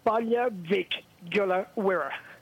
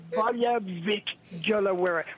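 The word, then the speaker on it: Baile Mhic Giolla Mhuire: Balja Vic Gula Wira. Balja Vic Gula Wira, which is Irish for Bally Kilmurry.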